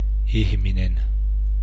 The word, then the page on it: ihminen: [ˈiçmine̞n]